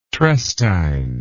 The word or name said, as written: trestine